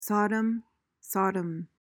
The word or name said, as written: sodom